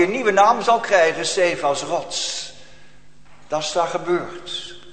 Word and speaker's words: Cephas: En gij zult Cephas genoemd worden. Of Kefas kun je ook uitspreken, Cephas.